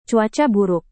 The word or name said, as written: cuaca buruk